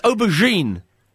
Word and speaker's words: aubergine: It's a soft G. It's it's aubergine.